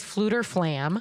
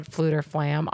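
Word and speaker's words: Fflewddur Fflam: His name is Fluter Flam.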